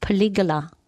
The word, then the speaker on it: polygala: Okay, so unfortunately with those little BB polygalas, botanically, because it's Latin, it should be pronounced polygala, but it sounds like something out of, you know, ancient history where they're going to murder your mother.